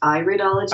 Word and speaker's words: iridology: And it's actually pronounced iridology because it has to do with the iris of the eye.